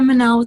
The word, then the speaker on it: Mennell: But yeah, just look for Laura Mennell with the check mark, and you'll know it's me. Yeah. – And if you ever say your name out loud, remember it's Mennell like Chanel.